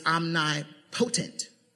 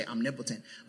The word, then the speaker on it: omnipotent: Number five, God is omnipotent. That's how you would spell that. We, we would say omnipotent, but the word is omnipotent.